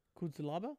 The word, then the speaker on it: Cutelaba: How do you pronounce this? It's like cute, Luba, Luba, Cutelaba. Ian Cutelaba.